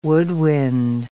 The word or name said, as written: woodwind